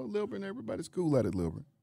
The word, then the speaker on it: Lilburn: I didn't say it like that. Just Lilburn.